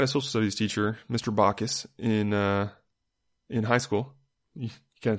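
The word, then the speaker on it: Backus: He pronounced it Bacchus.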